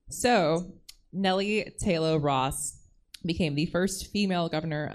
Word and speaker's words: Ross: Nellie Taylor Ross, I want to say Rose, but it's Ross.